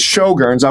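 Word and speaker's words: Sjögren's: Again, that we're talking about Sjogren's, and that is spelled S-J-O-G-R-E-N. Apostrophe S disease. That's for our listeners, Dr. Bob. So it's Sjogren's.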